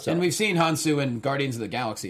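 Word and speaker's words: Hounsou: Hansu.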